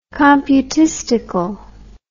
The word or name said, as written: computistical